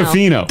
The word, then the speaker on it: Tofino: Then we went to Tofino, and they're like, stop hitting toe hard. – Tofino. – It's Tofino. – That's you in a nutshell. Someone pointed out Pearl Jam. – Yeah, that's true. – Pearl Jam. – Tofino.